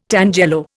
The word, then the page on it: tangelo: Indian